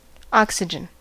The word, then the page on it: oxygen: American English